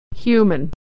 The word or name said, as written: human